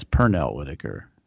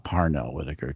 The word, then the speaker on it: Pernell Whitaker: I mean, it's Pernell Whitaker. Not Parnell Whitaker.